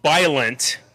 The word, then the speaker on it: Biollante: So it's Biollante, Biollante, B I O L B I O L L A N T E.